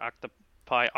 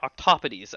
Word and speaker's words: octopodes: Octopodes, actually, would be the proper pronunciation. – I've learned something today. – Yeah, because it's Greek, I believe, or something, and the pluralization in Greek is not octopi, it would be octopodes.